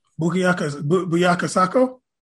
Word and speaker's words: Bukayo Saka: Bukayo Saka? – Yeah.